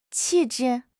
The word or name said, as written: 气质